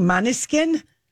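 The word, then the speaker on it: Måneskin: Maneskin.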